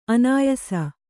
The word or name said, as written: ಅನಾಯಸ